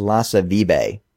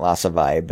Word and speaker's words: Lasse Vibe: We talked a little bit last week about apparently it's Lasse Vibe. Not Lasse Vibe, but as anyone that listens to this podcast knows, I can't pronounce English names properly.